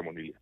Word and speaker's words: monilia: causa la monilia.